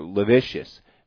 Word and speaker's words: lascivious: Rude behavior, rude language, acting rude, towards other, or lewd or lascivious, behavior. Is that the right word? Right? – Lascivious. – Lascivious. What does lavicious mean? – It's just mispronounced lascivious.